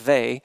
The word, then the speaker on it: Weil: Her last name looks like whale or while, but it's pronounced Weil.